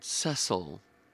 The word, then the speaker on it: Cecil: In 1907, he and George Cecil Jones, because that's how you say it in Britain, Cecil, co-founded an esoteric order called the AA.